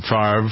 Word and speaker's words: Favre: That's how you pronounce his name, Favre.